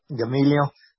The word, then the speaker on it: Gamaliel: Gamaliel.